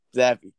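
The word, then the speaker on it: Zappe: But my number six guy is going to be Bailey Zapp. That's how you say it, right? – Zappy.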